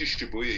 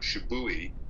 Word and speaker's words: Shibuya: Or he couldn't even pronounce Kenji Shibuya. He called him Shibui.